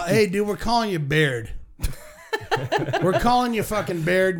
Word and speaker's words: Baird: I believe his last name is pronounced Baird or Bayard. – B-A-I-R-D? – Yes, Baird.